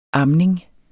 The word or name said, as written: amning